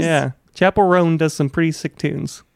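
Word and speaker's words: Chappell Roan: I just like a week ago discovered who, like, I'm gonna say the name wrong, Chapel Rowan is it Chapel Roan? – I thought it'd be Chappelle Roan, perhaps. Is it Chapel Rowan? – Chapel Roan. – Chapel Roan. – Yes.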